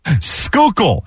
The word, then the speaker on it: Schuylkill: Schuylkill, as in Schuylkill Expressway or Schuylkill River.